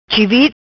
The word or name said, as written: Chivit